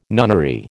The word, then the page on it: nunnery: American English